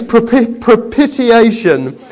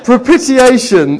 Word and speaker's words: propitiation: The first is propitiation. Yeah, thank you. Propitiation. That's not quite how you say it, is it? Someone say it. I can't. Propitiation. That's it. Yeah, I knew that wasn't quite right.